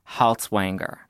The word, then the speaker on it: Haltiwanger: I have Elite Daily's senior politics writer, John Haltiwanger.